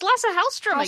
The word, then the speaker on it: Lasse Hallström: The director is, how do you say his name? – It's Lasse Hallström again. – Hallström, yes.